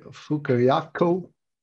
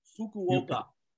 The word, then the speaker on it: Fukuoka: The other one is One Straw Revolution, Revolutionary, the philosophy and work of, I can't even say his name, Masanobu Fukuyaku. – Fukuoka.